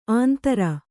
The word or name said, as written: ಆಂಧ್ರ